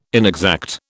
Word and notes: inexact: British English

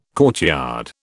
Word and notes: courtyard: British English

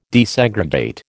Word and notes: desegregate: American English

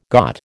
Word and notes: got: American English

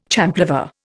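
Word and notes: champlevé: Indian

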